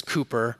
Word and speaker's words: Cowper: His last name is spelled like Cowper, but it's Cooper.